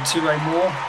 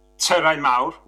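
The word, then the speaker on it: Tyrau Mawr: So, I'm just going to correct your pronunciation. So it's a Touré Mawr, which means big towers,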